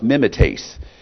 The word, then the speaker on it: mimetes: Now, the Greek word translated imitators is, I think it's called mimet. It's pronounced mimetes.